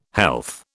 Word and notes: health: British English

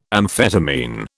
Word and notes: amphetamine: British English

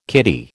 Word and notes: kiddie: American English

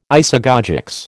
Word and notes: isagogics: American English

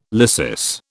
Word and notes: lysis: British English

